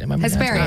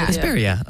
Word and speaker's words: Hesperia: Hesperia. – I have no idea. – Hesperia.